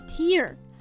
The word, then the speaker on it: tear: 而 tear 在刚刚的俗谚中，意思是眼泪，当名词用，发音是 tear。